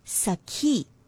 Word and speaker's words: sakhi: So it should sound like sakhi, not sukhi or sakhi, nothing like that. Sa, a simple sa like in sun, and a aspirated slight breath, ki, sakhi. All right, so that's how you pronounce the word sakhi.